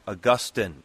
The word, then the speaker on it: Augustine: But when we refer to the church father, this theologian of ancient days, his name is pronounced Augustine, in contrast to the city in Florida.